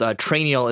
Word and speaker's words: Trainual: Trainial is another option. So it's train, like spelled like manual. So train, T-R-A-I-N-U-A-L.